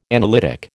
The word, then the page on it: analytic: American English